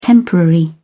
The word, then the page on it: temporary: British English